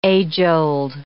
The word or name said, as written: age-old